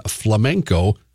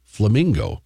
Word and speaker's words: flamenco: Wheel of Fortune contestant had a $7,100 prize erased when he mispronounced the word flamenco, instead saying flamingo.